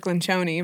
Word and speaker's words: Lencioni: If you haven't gone through that book with Patrick Lencioni, did I say that correctly? – I would say Patrick Lencioni, but...